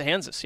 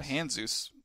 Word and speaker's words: Hanzus: Do you really pronounce his name Hansis? Like rhymes with Kansas? – That's Hansa Hansis. Yeah, H-A-N-Z-U-S. – I've been calling him Hansus.